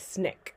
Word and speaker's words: SNCC: Another one is the Southern Christian Leadership Conference or SCLC, and then the Student Nonviolent Coordinating Committee, which is pronounced like SNCC apparently.